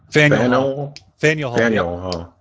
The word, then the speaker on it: Faneuil Hall: Is that how you say it? Is that the pronunciation? – Faneuil Hall. Right.